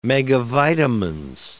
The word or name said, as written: megavitamins